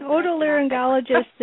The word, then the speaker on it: otolaryngologist: otolaryngologist? – Yes, an otolaryngologist is a physician,